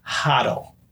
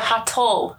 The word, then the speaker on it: HODL: It is pronounced HODL. – Oh. HODL? – HODL. – HODL or HODL? – HODL. With a D. – HODL. Oh. Like huddle? – Like huddle. HODL.